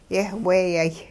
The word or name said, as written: yehweeyay